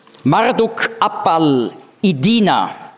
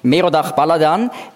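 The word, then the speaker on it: Marduk-apla-iddina: Dieser Merodach Baladan, oder babylonisch ausgesprochen, habe ich das erklärt auf Seite 1 ganz am Schluss, Marduk Apal-Idina. Dieser Marduk Apal-Idina, oder hebräisch ausgesprochen, Merodach Baladan, wird ausdrücklich in der Bibel erwähnt.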